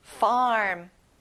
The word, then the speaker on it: farm: 농장, farm. 입을 크게 벌려서 R 소리를 내보세요. Farm. Very good.